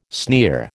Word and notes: sneer: American English

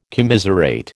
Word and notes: commiserate: American English

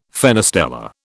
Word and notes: fenestella: British English

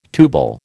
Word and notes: tubal: American English

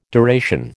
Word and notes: deration: American English

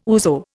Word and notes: ouzo: Indian

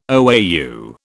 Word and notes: OAU: British English

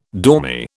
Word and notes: dormy: British English

